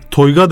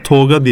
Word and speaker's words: Toyga: Toyga derler diğer şehirlerde. Bizim orada Toga diye telaffuz edilir kıymetli dinleyenlerimiz.